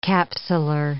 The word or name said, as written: capsular